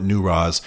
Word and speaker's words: Newroz: It's pronounced a variety of ways, but my local Kurdish friends call it Newroz.